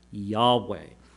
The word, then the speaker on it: Yahweh: So we know that the name here, the YHWH pattern, was pronounced, most likely would have been pronounced had they pronounced it, as Yahweh.